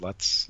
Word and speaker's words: Lutz: I think it's Lutz. I think that's how you pronounce that. L-U-T-Z.